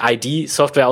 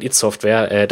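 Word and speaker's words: id Software: Und deswegen nicht ID-Software aussprechen, sondern It-Software.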